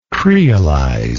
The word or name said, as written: creolize